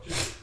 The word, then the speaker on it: Tudyk: Yeah, I was talking about Alan Tudyk earlier. Isn't that how you pronounce it? Tudyk. Alan Tudyk. – Oh, Tudyk.